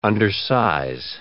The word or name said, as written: undersize